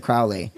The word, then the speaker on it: Crowley: well, you're a little young for this, but Ozzy Osbourne did a song called Mr. Crowley. And it was very big in the 80s, but he was saying it wrong. So whenever you say Crowley, think of holy. – Crowley is holy.